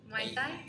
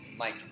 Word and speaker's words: Muay Thai: I can always say that wrong, but Muay Thai? – Muay Thai, yeah.